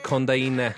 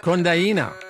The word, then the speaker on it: Kondaine: Let's listen to one of the songs off it, Konden, and then talk about it. – You pronounce it Kondaine. – Kondaine. – Yeah, Kondaine.